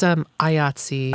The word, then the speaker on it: IATSE: It's IATSE.